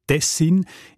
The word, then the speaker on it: Tessin: Auch im Hochdeutschen sieht man Tessin. Die Variante mit der Betonung auf den ersten Silbe und mit kurzem I, also Tessin, ist seltener.